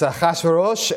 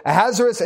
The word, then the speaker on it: Ahasuerus: I have to remember to pronounce things in a way that Americans understand them, because in Hebrew, they're pronounced completely different. It's Ahasuerosh and Haman. All right. So Ahasuerus and Haman.